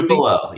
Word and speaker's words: Tupelo: Tupelo. Yes. – Tupelo. Tupelo, okay. – Honey, it's Tupelo. Birthplace of Elvis. Come on, Rami. – I love it. – Yeah. – I love it. – Yeah. Tupelo, Mississippi.